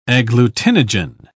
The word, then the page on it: agglutinogen: British English